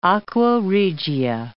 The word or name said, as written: aqua regia